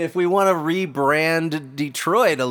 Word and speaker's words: Detroit: So wouldn't it be pronounced Détroit? – Oh. – Have we been saying it wrong this entire time? – Can you imagine? – Détroit, Michigan. – He's like, where are you from? – Détroit. – It doesn't have the same... Like, Detroit sounds like you might get shanked up in an alley...